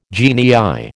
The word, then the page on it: genii: American English